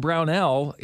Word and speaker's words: Brownell: Brad Brownell is not I said that right. Brownell. – Yeah. – Yes, there we go. – Perfect. That's the first time you said it. Absolutely correct.